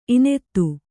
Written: ಇನೆತ್ತು

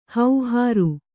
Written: ಹೌಹಾರು

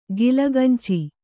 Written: ಕಲಗಂಚು

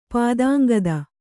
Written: ಪಾದಾಂಗದ

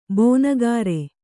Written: ಬಣಕಾರ

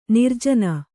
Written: ನೀರಾಜನ

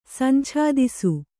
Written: ಸಂಛಾದಿಸು

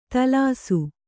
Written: ದೋಲಿಸು

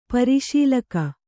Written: ಪರಿಶೀಲಕ

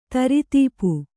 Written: ದಾರಿದಪ್ಪು

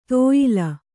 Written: ತೇಯೆಲೆ

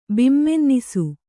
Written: ಬಿಮ್ಮೆನ್ನಿಸು

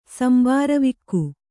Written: ಸಂಬಾರವಿಕ್ಕು

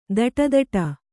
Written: ತೊಡೆದಟ್ಟು

